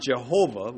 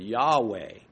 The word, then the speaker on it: Yahweh: the closer to the proper pronunciation, you got to learn how to pronounce pronunciation first, is not Jehovah with a J, but Yahweh.